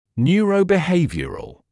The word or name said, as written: neurobehavioural